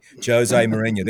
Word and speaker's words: Jose Mourinho: Jose Mourinho.